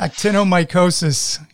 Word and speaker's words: actinomycosis: There you had it, actinomycosis. Or how would you say it? You were the expert in pronouncing it. – Actinomycosis. – Yes. Okay, good.